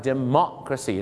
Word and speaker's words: democracy: หลายคนชอบออกเสียงว่า democracy crazy แปลว่าบ้าอย่างนี้แปลว่าประชาธิปไตยบ้าหรือเปล่าครับผมแหมไม่ใช่นะครับประชาธิปไตยต้องออกเสียงว่า democracy นั่นเองครับเมาะฮะเหมือนป่าละเมาะอะไรเงี้ยนะ